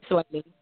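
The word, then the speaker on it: Swae Lee: Sway Lee? How you say his name? – Sway Lee. Mm-hmm.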